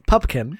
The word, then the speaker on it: Pupkin: Rupert Pumpkin. – Thank you. Not Pumpkin. Pupkin. – Yes. Not Pumpkin. Not Pipkin. Not Pipkin. Not Plimpton.